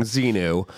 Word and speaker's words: Xenu: That's what Xanu wants. – Sure. Xenu. – Whatever. – Please pronounce her name correctly. – Their name correctly. The being, Xenu.